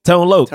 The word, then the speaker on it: Tone Loc: Tone Loc.